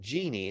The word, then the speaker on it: Geeni: And Genie, it's spelled G-E-E-N-I, is listed there.